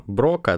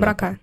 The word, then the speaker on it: Брока: Зона Брока, да, пишется? – Она? Брака.